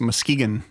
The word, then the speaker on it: Muskegon: By the way, Dan, as a one-time Michigan resident, I must correct you that it's Muskegon.